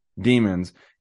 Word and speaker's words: Daemon: They pronounce it demons, and you have Block Daemon as a company, and the logo is literally a cube with horns.